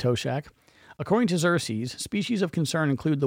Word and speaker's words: Xerces: Montana kicked off its documentation efforts this year with Xerces Society Invertebrate Conservation leading the effort. Livingston-based Xerces, and I hope I'm pronouncing this right, it's X-E-R-C-E-S, so I'm going with that till someone tells me otherwise.